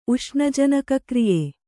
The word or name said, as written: ಉಷ್ಣಜನಕಕ್ರಿಯೆ